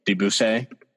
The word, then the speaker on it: Debussy: Debussy, I think. – Debussy.